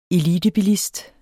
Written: ELITEBILISTER

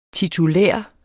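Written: TITULÆRT

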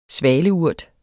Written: svaleurt

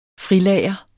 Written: frilager

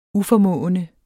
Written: uformående

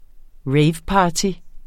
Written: raveparty

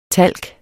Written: talk

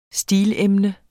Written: stileemne

